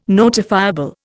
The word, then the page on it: notifiable: Indian